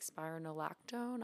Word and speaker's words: spironolactone: And then I take spironolactone.